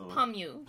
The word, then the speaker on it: Pamyu: And then the other one I got recently, with the same time, is by Kiari Pamyu. I hope I'm pronouncing that right. Because her last name is Pamyu. So Pamyu. Yeah, that sounds about right.